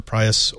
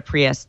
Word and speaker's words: Prius: Well, you're going to be in Seattle, so the only two cars that are acceptable are either a Prius or a Tesla. – A what? – Yeah. – A Prius, sorry. I used the Top Gear pronunciation for a second there.